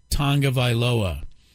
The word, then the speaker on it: Tagovailoa: Tua Tagovailoa. That is the correct pronunciation.